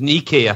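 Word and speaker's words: Niké: It's got a little accent on the E or the I. It's Nike, I think they would say it.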